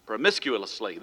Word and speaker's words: promiscuously: promiscuously. I can't, I'm not saying that. Prom- promiscuously. There we go. I put the emphasis on the wrong syllable.